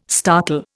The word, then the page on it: startle: Indian